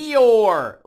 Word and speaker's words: Eeyore: That's, Eeyore, like, like from Disney. Like the donkey? – Man, I wouldn't go with that.